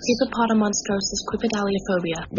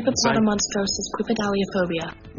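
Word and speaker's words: hippopotomonstrosesquippedaliophobia: The fear of long words is called hippopotomonstrosquipedialophobia. Let me see if YouTube can do a better job of pronouncing this. – Hippopotomonstrosquipedalophobia. – There we go. That's much better.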